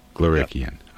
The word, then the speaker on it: Glorikian: Glorikian.